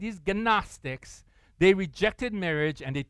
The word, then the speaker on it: Gnostics: It's spelled G-N-O-S-T-I-C-S, Gnostics, okay?